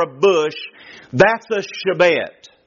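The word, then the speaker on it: shebet (שבט): The word rod in the Hebrew is the word pronounced shebet. S-H-E-B-E-T.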